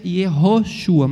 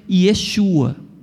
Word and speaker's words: Yeshua: A pronúncia da época depois do cativeiro não se pronuncia Yehoshua, mas se pronuncia Yeshua.